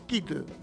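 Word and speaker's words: quid: Kit, palabra de origen latino que significa razón, esencia, porque de una cosa va precedido el artículo el y su pronunciación es kit y no quit.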